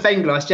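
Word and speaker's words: Vengloš: How do you say his name? Go on, you pronounce it. – Dr. Joseph Venglos. Yeah, you got it spot on.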